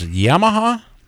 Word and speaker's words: Yamaha: And of course, is it pronounced Yamaha? – That's it.